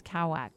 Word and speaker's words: Kawak: Storm is also, but it's pronounced in Mayan, it's Kawak.